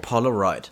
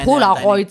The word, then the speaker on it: Polaroid: Das ist schön, wie du es Englisch aussprichst. Polaroid.